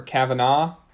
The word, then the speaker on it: Kavanaugh: How do you say it? Kavanaugh or Kavanaugh? – Kavanaugh. – Kavanaugh, Kavanaugh.